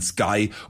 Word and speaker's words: Sky: Das eine heißt nicht Skü, sondern Sky und das andere spricht man der Sohn aus.